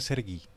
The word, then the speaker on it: Sergei: Sí, y se pronuncia Sergi. – ¿Sergi? Sí, Sergi. O sea, no es Sergei, sino Sergi. – No es Sergei, sino Sergi. Sergi de, de buena onda. – No, o sea, se escribe Sergei, pero la pronunciación es Servi.